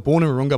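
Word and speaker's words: Wahroonga: Yeah, so I was born in Warunga, but I grew up on the northern beaches of Sydney, out in Manly Way, Manly Way.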